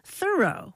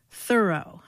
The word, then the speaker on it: Thoreau: pronounced Thoreau, with the accent on the first syllable. And there's a new Ken Burns film about him on PBS, and the voice actors for that film, including George Clooney and Meryl Streep, were all directed to pronounce his name thorough.